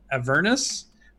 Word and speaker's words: Avernus: And Jeremy was saying the way he tries to remember is he tries to remember a furnace. So Avernus.